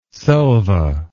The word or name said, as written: selva